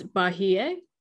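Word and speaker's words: Bahiyyih: I'm gonna attempt to say her name because I was based on what the Hangul is. It's Bahie. – Mm-hmm. Yeah, because in her romanized name, it's the last part is Y-I-H.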